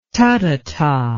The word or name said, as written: tathata